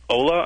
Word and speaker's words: Hola: You know, after I posted this, some people referred me to this one called Ola, I think it's pronounced, H-O-L-A.